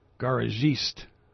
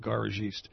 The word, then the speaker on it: garagiste: It's French. And let me see if I remember how I thought I was going to pronounce it. Garagiste. That's how I'm going with garagiste.